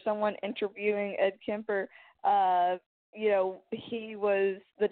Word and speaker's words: Kemper: Or, it's K-E-M-P-E-R, so Kemper, yeah.